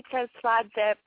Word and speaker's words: Tsertsvadze: My whole name is Sally Tsertsvadze Pramraj.